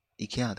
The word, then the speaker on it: IKEA: 就是东南亚某一个国家的 IKEA 的官方的。脸书还是什么社群平台，就是教大家怎么念，是要念 IKEA 的样子，所以我就念 IKEA，就现在就目前改念 IKEA，我觉得蛮合理的啊。